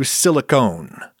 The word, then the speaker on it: silicone: Now we come to silicone, with a silent E at the end of it.